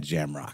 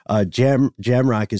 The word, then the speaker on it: Jamrach: Jamrock is spelled J-A-M-R-A-C-H, and made the after looking through some pronunciation possibilities, I just decided to say Jamrock as well. For this, it sounds cooler.